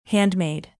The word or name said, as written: handmade